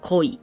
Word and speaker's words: 恋: 比如说像日文现在的“ 恋爱” 的“ 恋” 这个字呢，就叫做 “koi”。